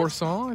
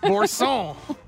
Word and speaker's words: Boursin: Some people offering to substitute the feta with, is it boursin? Is that how you say it? – Boursin, I think. – Oh, well. – You're putting a little stank on the end. Boursin.